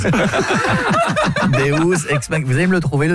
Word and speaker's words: Deus ex machina: Deux ex machina. Deus ex machina. – Ah, parce que deux, j'aurais dit que c'était deux ex que t'appelles machine. – Non, ça se prononce Deus ex machina.